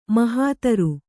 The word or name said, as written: ಮಹೀಧ್ರ